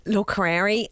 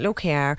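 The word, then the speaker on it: Locorriere: Yes, Dennis Locarieri. I think I've maybe pronounced that incorrectly. – Probably. – Somebody told me earlier, Locarieri.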